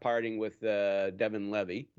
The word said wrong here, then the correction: Levi: Did I say his name right? Devon Levy, right? – Levi. – I want to keep calling him Levi. – I think it is Devon Levi. – I think it is Levi.